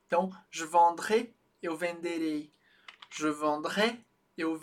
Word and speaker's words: vendrais: Além disso, na pronúncia a gente marca uma distinção importante. No condicional a gente faz um som mais aberto. Je vendrais, eu venderia.